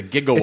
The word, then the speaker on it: gigawatt: So when a lot of people are surprised to find out that it's not JIGG, it's GI. It's a gigawatt.